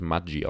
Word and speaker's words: Maggi: Das äh, ist eigentlich was Italienisches. Und deswegen wird es eigentlich Maggi ausgesprochen.